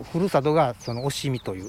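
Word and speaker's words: 忍海: おしみという、しのぶ海と書いて、はい、おしみと言いますが、はい、おしみ地方から出てきた釘と同じタイプのものだということが、はい、分かったわけですね。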